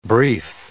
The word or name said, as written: brief